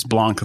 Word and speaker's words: Blanc: And so that's just kind of the common pronunciation, but it's Blanc, like Mount Blanc or like the pens, stuff like that.